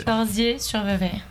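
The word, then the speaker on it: Corsier-sur-Vevey: Le 12 septembre, est-ce que je prononce bien, vous serez à Corsières-sur-Vevey. – Presque. Corsières-sur-Vevey.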